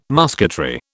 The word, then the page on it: musketry: British English